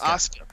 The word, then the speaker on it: Asuka: How do you Asuka. – pronounce her name? – Asuka. – Asuka.